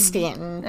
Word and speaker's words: Staunton: Am I saying that correctly? Salton? You lived in Virginia. – Where is it? Staunton. – Is it Stanton? – It is Stanton, yeah. So I'm like, it doesn't look like Stanton.